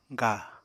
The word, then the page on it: ga: apatani